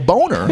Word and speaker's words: Boehner: Because Boehner, you know, Boehner, his dad was a boner. No, he was. – That was coming. – That's how the name was pronounced until he got it in politics, and he changed it.